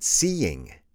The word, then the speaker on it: seeing: Please repeat seeing. Remember, that is the gerund of the verb to see, and it has two syllables. It's not like the participle of the verb to see, which is seen.